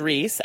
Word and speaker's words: Rhys: I know one other guy named Reese, and it's spelt like this. R-H-Y-S.